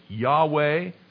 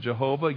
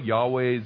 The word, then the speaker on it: Yahweh: And this name, I am, of course we pronounce it in English, Yahweh. Some pronounce it Jehovah. Yahweh is probably a better guess at how it's actually pronounced.